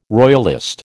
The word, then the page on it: royalist: American English